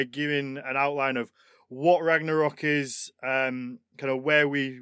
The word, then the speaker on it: Ragnarok: We're going to be looking at the Norse apocalypse, which is Ragnarok. I'm sure I'm butchering the pronunciation of it. – Oh, you're fine. Don't worry about it. It's an English word too nowadays.